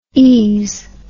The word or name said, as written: ese